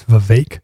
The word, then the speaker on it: Vivek: I always pronounced it Vivek. It is Vivek.